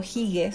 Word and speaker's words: Hygge: Y él escribió este Little Book of Hygge, que es este concepto, Hige, se escribe H-Y-G-G-E.